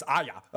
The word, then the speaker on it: Arya: It's not Arya. It's Arya. Uh, so, but uh, I'm I, I mispronounce the sometimes.